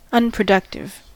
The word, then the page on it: unproductive: American English